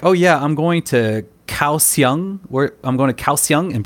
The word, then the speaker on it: Kaohsiung: Kaohsiung is spelled K-A-O-H-S-I-U-N-G. So even though it's spelled with a K, it's pronounced with a G sound.